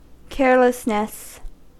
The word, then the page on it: carelessness: American English